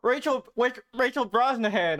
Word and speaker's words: Rachel Brosnahan: Oh, Rachel Brosnahan, I think is how it's said.